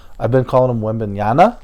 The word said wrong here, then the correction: Wembanyama: I just realized that I've been saying his name wrong the whole time.